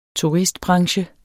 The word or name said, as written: turistbranche